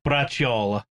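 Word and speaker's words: braciola: Brujol is the Sicilian American way of saying it. It's bracciola. – Bracciola. – Bracciola.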